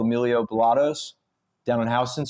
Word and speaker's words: Houston: By the way, it's pronounced Houston Street. It's Houston.